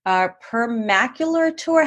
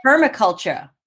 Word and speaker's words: permaculture: Permacular tour. How do you say that? – Permaculture? – Permaculture.